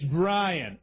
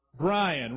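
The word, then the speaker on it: Bryan: Is when somebody calls me Byron. I hate that. That is not my name. It's Brian. It's spelled with a Y, but get out your phonics dictionary. That still means what? Brian, right?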